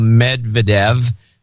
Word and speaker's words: Medvedev: The fact of the matter, he says, with a smiley face, is that he was correct since this is how Medvedev's name is pronounced in Russia, with the stress on the last E, Medvedev.